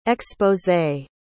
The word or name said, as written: exposé